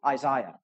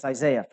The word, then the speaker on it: Isaiah: Well, then you need to hear and soak in every word that I almost said Isaiah. That's how the British say it. They sound real cool. Isaiah. But it's Isaiah for us.